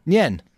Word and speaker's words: Nyan: Nyan? – Nyan.